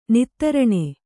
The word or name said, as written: ನಿತ್ತರಣೆ